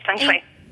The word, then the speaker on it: feng shui: Am I pronouncing it right? – Yes, feng shui.